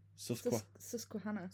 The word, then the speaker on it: Susquehanna: Susquehanna. – Susquehanna, yeah.